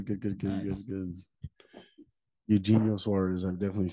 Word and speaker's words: Eugenio: Eugenio. – Eugenio, there it is.